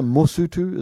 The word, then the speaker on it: Mosotho: A singular Mosotho is one person from that country.